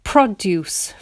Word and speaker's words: produce: Produce from the area. I'm putting the stress on the first syllable, produce, which means it's a noun.